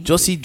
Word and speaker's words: Jussie: This Jesse, is his name Jossie or Jesse? – I don't know, man. – Jossie, is J-U-S-S-I-E. – Does anyone know Jossie's Giants? – How do you spell it? J-U-S-S-I-E.